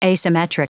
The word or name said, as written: asymmetric